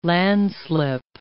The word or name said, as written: landslip